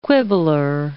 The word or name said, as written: quibbler